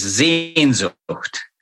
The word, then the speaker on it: Sehnsucht: That word is Sehnsucht. – One more time. – Sehnsucht.